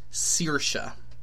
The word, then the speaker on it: Saoirse: S-A-O-I-R-S-E, which is an Irish word for freedom, and is pronounced Saoirse. But she makes a huge deal going all around the world telling everybody she pronounces it Saoirse like inertia.